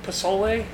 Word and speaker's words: pozole: Pozole? – It's spelled pozole. P-O-Z-O-L-E. – P-O-Z-O-L-E.